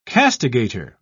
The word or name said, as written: castigator